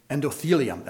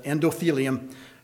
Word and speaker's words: endothelium: If you have that lining of your arteries healthy, the endothelium, that's how you pronounce it, endothelium healthy, then cholesterol is not going to be an issue.